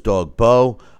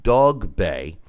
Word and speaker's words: Dogboe: I, I, thought it was Dog Bow.